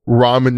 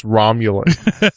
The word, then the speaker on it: ramen: Like for example, ramen noodles, I used to pronounce Romulan. So there's no L in it, but they were Romulan noodles to me.